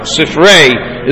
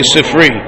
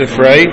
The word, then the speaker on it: Sifrei: And Bamidbar and Dvarim have what's called Sifrei, is really the right pronunciation. Most people say Sifri, including myself. But the right pronunciation is Sifrei, and it comes from this.